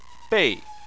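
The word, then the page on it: bei: tone 4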